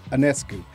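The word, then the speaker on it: Sabrina Ionescu: But Liberty, of course, losing their star player, rookie Sabrina Anoscu. Did I say it right, Mike? Boy, I tell you, boy. Hooked on Faunus work for me. – you're on that one. – See? Anescu. Looking good.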